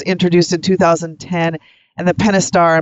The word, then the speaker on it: Pentastar: Well, Tony and Brian, the 10 millionth Pentastar engine was produced on February 13th at the FCA U.S.